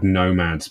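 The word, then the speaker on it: Gnomads: And then the other ones that are fun, there's a little group of, they're called nomads, but it's spelt with a G, like a silent G. No, you know, they're gnomes, but they're nomads.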